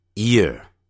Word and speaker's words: ear: 我们来看 contrast 比较比较有 y 加元音或无 y 加元音的念法我们来看一下年 year year year year 记住如果你把前面的 y 的音去掉 air air 是耳朵年的时候念成 ear ear 所以耳朵 ear ear 再把它念一下年 year year 耳朵 ear ear 有差别的哦